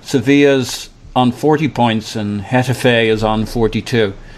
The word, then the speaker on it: Getafe: I was just looking at the 538 rankings, and they have Getafe as the, the number one team to win the Europa League right now, which is pretty crazy to me. – I think it's um, pronounced yeah, Hetafe.